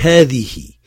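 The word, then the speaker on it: Hadihi (هذه): Et hadi, c'est encore plus compliqué. Hadihi. Il faut absolument prononcer ainsi et les écrire tel que c'est dans l'affiche. Les prépositions, on a deux colonnes.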